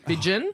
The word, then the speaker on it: Vidgen: Vidgen. – Vidgen? – Jack Vidgen.